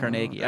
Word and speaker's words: Carnegie: I read it's Carnegie, not Carnegie. – Really? It's Carnegie? – Yeah, because he's Scottish and it's pronounced Carnegie.